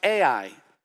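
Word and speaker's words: A-I (εἰ): The word since in Greek is a little two-letter word, E-I, and it's pronounced A-I.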